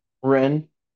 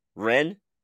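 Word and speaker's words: Wren: how do you pronounce her first name? – Ren. – Ren? The W is silent.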